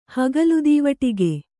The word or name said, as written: ಹಗಲುದೀವಟಿಗೆ